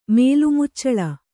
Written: ಮೇಲ್ಮುಚ್ಚಳ

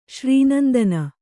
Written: ಶ್ರೀನಂದನ

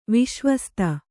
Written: ವಿಶ್ವಸ್ತ

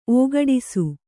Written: ಒಗಡಿಸು

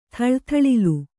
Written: ಥಳಥಳಿಲು

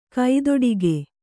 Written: ಕೈದುಡುಕು

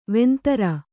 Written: ವಂದರಿ

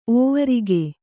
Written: ಓವರಿಗೆ

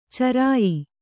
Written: ಚರಿಯ

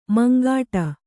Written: ಮುಂಗಡೆ

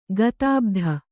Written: ಗತಭೀತ

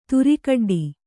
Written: ದಾರೆಕಡ್ಡಿ

